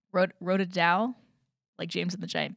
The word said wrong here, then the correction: Roald Dahl: Roald Dahl.